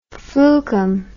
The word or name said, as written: flukum